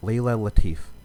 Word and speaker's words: Latif: But we have a special guest from the Final Girls podcast, freelance writer, Layla Latif. – That's right. You pronounced it right.